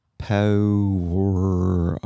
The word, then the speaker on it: Powerage: By the way, Powerage is pronounced Powerage. – It's an Aussie thing.